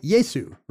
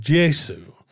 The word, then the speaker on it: Gesù: And today, you know, an Italian would just say Yesu, right? Like he would use the G. – Actually, in Italian now, it's pronounced Gesù, Gesù.